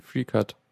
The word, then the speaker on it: FreeCAD: Also wir haben da zum Beispiel AutoCAD, ja, das, äh, das typische, oder CAD, weiß ich nicht, wie, wie spricht man es am besten aus? Ich kenne... – Free, free, free Cut. – Okay, dann einfach FreeCut.